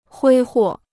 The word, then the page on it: 挥霍: huī huò